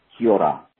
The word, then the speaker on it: Kia ora: And the greeting that you guys were trying to say is pronounced Kia ora.